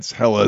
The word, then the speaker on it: Hela: No, that's Hela is the pronunciation. I said it wrong. I guarantee if they say in the movie, that's how it's pronounced.